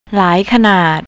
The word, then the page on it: laikanard: thai